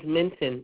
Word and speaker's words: Minton: My last name is Minton. M is in Mary, I, N is in Nancy, T is in Tom, O, N is in Nancy.